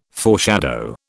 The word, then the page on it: foreshadow: British English